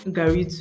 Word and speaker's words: garri: I don't know how to describe garri to my non-Nigerian people, but yeah, just Google garri. It's pronounced G-A. It is spelled G-A-R-R-I.